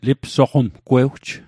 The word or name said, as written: lipsoqomgwewj